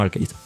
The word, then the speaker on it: Arceus: Sí, lo, pronuncian como Arceus.